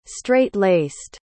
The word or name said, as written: straight-laced